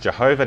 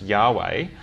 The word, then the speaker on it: Yahweh: They thought it was probably pronounced Jehovah. Now we think it's probably pronounced Yahweh.